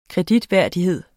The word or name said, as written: kreditværdighed